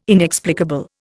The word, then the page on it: inexplicable: Indian